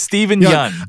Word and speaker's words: Steven Yeun: It's Steven Yeun.